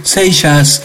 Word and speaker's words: Seixas: Maluco Beleza, Raúl Seiza, es, o no sé cómo se pronuncia. – Seixas. – Seixas.